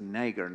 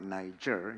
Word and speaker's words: Niger: There was a young lady of Niger, and I'm going to pronounce it as Niger, not Niger.